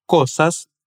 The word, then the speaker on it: cosas: Aqui, quero chamar a sua atenção para a pronúncia do S, nas palavras cosas e mesa. O som é de S mesmo, não de Z como em português.